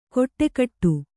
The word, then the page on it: ಕೊಟ್ಟೆಕಟ್ಟು: koṭṭekaṭṭu